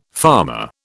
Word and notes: farmer: British English

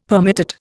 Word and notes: permutate: Indian

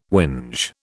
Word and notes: whinge: British English

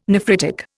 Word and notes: nephritic: Indian

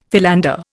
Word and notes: philander: Indian

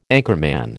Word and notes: anchorman: American English

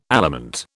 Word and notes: aliment: British English

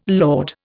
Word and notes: lord: Indian

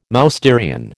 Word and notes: Mousterian: American English

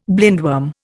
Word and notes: blindworm: Indian

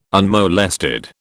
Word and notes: unmolested: British English

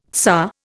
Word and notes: tsar: Indian

